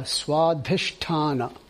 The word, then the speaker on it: Swadhisthana: Swadhisthana.